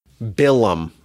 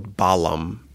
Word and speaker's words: Balaam: Next week's Parsha, we meet one of the strangest characters in all of Torah, Bilam, or Balaam, pronounced in English.